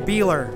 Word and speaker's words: Biehler: B-I-E-H-L-E-R. – And pronounce it how you like it pronounced, because someone told me I was wrong. – It's Beeler.